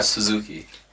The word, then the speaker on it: Suzuki: Shinryu uh, Suzuki.